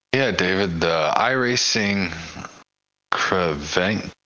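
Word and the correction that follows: Creventic: Did I say that right, David? – I don't know. – Kreventic. – It's Kreventic.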